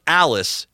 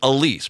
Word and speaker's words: Elise: Drazkowski is, I think, making fun of the fact that it's not pronounced Alice, it's Elise, because she's from, believe it was Brazil.